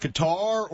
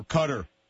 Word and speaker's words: Qatar: Is it pronounced Qatar or Qatar? – They accept both. It's, neither is actually correct in, when said in Arabic, but they will accept both.